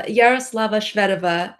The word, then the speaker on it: Yaroslava Shvedova: Yaroslava Shvedova, and I've had many chances to practice it.